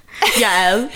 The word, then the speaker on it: Yael: Yael. I mean, I say it differently based on how I think people can pronounce it, which is also on me.